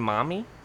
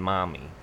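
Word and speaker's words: Marmee: So in the regional dialect of the time, even though you would spell Marmy, like, that region, you didn't pronounce R's, so really Louisa May Alcott mommy. – Is writing Marmy, she's saying mommy.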